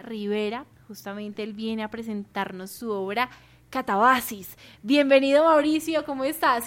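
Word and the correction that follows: Catábasis: Casi perfecto, es Catabasis. – Catabasis, sí, ¿ves? – Con la acentuación en la segunda.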